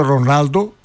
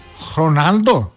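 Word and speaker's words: Ronaldo: Ότι στα πορτογαλικά το γράμμα ρο που λέμε εμείς Ρονάλντο ουσιαστικά προφέρεται σχεδόν σαν χ. Άρα είναι Κριστιάνο Χρονάλντο.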